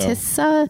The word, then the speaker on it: Tisza: Tisza. Am I saying that right? – I think so. – Tisza. – T-I-S-Z-A.